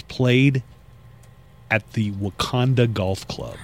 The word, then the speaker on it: Wakonda: Exactly the same, except there's an O. Wakanda. W-A-K-O-N-D-A. Wakanda. But let me tell you, you pronounce it Wakanda because I was so starved for golf,